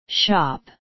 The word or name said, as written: shop